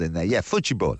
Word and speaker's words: futebol: To get the pronunciation right, if I was in Brazil right now, it would be Futebol Nation.